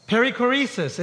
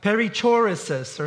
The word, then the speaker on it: perichoresis: I didn't pronounce it that way at first. I'm having trouble remembering how I pronounced it now because I only say it the right way. But I think it was something like perichoresis or something like that. But it's perichoresis, and we're going to talk about this idea today.